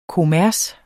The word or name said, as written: kommers